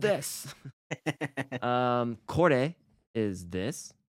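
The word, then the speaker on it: Kore (これ): Kore, pronounced ko-re.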